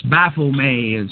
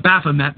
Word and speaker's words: Baphomet: And I'm sorry, I'm blowing the name totally. I've never been able to pronounce it, but... – Well, he's your leader. You don't even know how to say his name. I am offended. – it's another one of those French things. It's Baphomet is how, if you drink Starbucks, that's probably how you pronounce it, but...